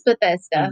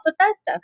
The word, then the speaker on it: Bethesda: Yeah, we went to, and I'm going to probably not say this right, Bethesda. I think it's Bethesda. – Mm-hmm. yeah. Myself, Bethesda.